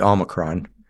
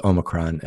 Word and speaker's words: Omicron: Well, Omicron is O micron, like it's a micro O is Omicron, and the Omega is the mega O, the last, and it's a long O.